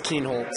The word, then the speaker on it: Kienholz: I've heard crazy pronunciations before, and I feel like a lot of them is Kienholz. That's probably the main one I get, yeah. But it is Kienholz.